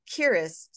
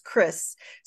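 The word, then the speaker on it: keris: And in one of the books that we got from the library, it had this little sidebar that talked about a Kiris sword, sometimes pronounced Kris sword.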